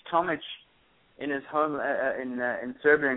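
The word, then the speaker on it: Tomić: Depends on part of the world you're in. If Eastern Europe, it's Tomic. Obviously with the Serbian Croatian pronunciation of the IC at the end.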